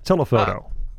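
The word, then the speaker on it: Telephono: I got this album in 1997, and I to this day still don't know whether it's pronounced telefono or telefono, like, you know, in the Spanish way. It's spelled with the PH, so I kind of anglicize it. – Right. It's Americanize it. It's telefono.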